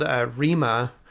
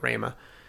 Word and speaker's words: Rhema: They're called Rema, or Rema. – R-H-E-M-A. – Yeah, I've heard it pronounced both ways, but I think the band themselves called it Rema.